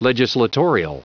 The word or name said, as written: legislatorial